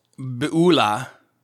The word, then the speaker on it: Beulah: Let's then pick up with Christian and Hopeful as they are leaving the land of Beulah. Beulah. It's an interesting thing about Hebrew. The emphasis so often on the ultima, the last syllable, and it makes things sound extra cool.